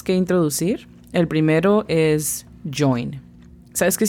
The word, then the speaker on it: join: La pronunciación fonética es casi como la CH o I-N, pero no tan duro. Con CH sería join, un poquito más suave, entonces join.